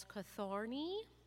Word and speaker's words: kothorni: So these were known as kothorni. – Okay. – Kothorni. Yeah, I said that right.